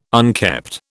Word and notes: unkept: British English

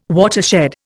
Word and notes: watershed: Indian

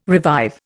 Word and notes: revive: Indian

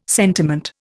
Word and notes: sentiment: Indian

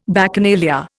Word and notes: Bacchanalia: Indian

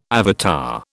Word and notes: avatar: British English

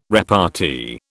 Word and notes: repartee: British English

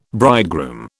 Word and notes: bridegroom: British English

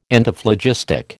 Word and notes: Antiphlogistic: American English

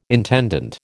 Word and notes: intendant: American English

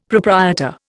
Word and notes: proprietor: Indian

